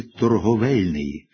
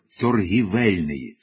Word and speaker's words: торговельний: Така ж помилка часто зустрічається в слові «торговельний», де відповідний відкритий склад багатьом чомусь здається закритим, і вони починають вимовляти і писати це слово як «торгівельний», без будь-яких підстав. У формах «торгівля», «торгівці» «і» вживається цілком закономірно. Але вже у формах «торговий», «торговельний» слід вживати «о».